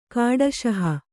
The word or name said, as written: ಕಾಡಶಹ